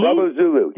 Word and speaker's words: Bravo Zulu: Okay, I'm looking at your Bravo. I think that's how you pronounce it. Bravo Zulu. – Bravo Zulu. Yes.